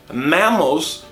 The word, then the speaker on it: mammals: Mammals são mamíferos. Observe que a pronúncia dele também é um pouco diferente. É mais aberto pro mammals.